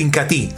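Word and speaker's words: Inca Tea: Es el incatí, se escribe incatea, pero se pronuncia incatí.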